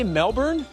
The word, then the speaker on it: Melbourne: How did you say Melbourne? – Melbourne. The correct That's the correct pronunciation if you ask an Australian.